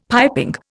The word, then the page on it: piping: Indian